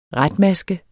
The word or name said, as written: retmaske